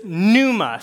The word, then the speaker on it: pneuma: And in the New Testament, it's a silent P. It's pneuma.